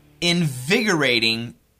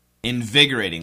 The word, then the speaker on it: invigorating: Today's expression is nice, listen carefully, an invigorating sleep. Invigorating.